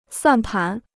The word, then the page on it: 算盘: suàn pán